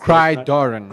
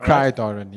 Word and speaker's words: Gridiron: I hope I've got that pronounced. – Gridiron. – Gridiron. – Gridiron. Not Cry-Doran, yeah.